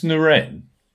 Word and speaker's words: Noren: How do you pronounce it, chaps? – And Noren will do.